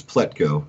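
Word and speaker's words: Plutko: I'm probably going to butcher his last name, Pletko. – Oh, yeah. You nailed it. It's Pletko.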